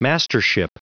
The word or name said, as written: mastership